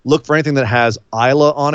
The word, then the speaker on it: Islay: It's spelled I S L A Y. Um, so it's it looks like Islay, but it sounds like Isla.